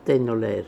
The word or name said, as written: tainolair